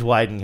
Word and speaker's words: Wieden: And I have never been 100% sure whether it was Whedon or Wyden. – Oh, yeah, that's right. So yeah. I went on like a YouTube hole. I went down a YouTube hole trying to find someone who pronounced the, the first name of Dan or the last name of Dan Wieden. – And so it is Wieden Kennedy.